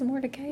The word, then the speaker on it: Mordecai: And I know a lot of people say Mordecai, but I really have looked at this word. I really looked at pronunciations, and it really is Mordecai.